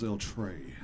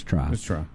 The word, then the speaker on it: El Tri: Huh? It's Tri. – Well, I've heard El Trey. – No, T-R-I. – I know, but they don't say it like that. They call it El Trey. – It's called El Trey. – Look it up. It's not El Trey.